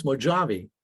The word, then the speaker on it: Mojavie: She goes, you know, Dad, if you took Mojave, just slap an I in front of the E, it'd be pronounced Mojave.